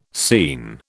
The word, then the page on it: seen: British English